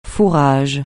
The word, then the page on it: fourrage: francais